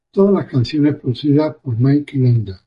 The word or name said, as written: producidas